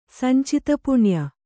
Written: ಸಂಚಿತಪುಣ್ಯ

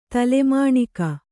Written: ತಲೆಮಾಣಿಕ

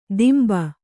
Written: ದಿಂಬ

